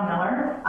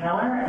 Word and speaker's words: Miller: My name is Ruth Abigail Miller. My name is spelled R-U-T-H-A-B-I-G-A-I-L, last name Miller, M-I-L-L-E-R.